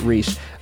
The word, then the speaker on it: Reiss: But the writer of that particular episode, I think his last name is spelled or pronounced Reese, but it's Mike, R-E-I-S-S, Reese.